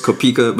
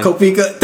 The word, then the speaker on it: Koepka: We had the PGA tournament and Brooks Koepka pulling it through. – I would just like to say his name is Brooks Koepka, but you know, it's fine. It's fine. – Koepka.